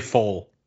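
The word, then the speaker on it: Pfohl: Larry Fole. – Yes, Larry Fole, with the P in front, with the silent P.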